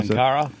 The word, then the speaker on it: Ankara: Yeah, Ankara, yep.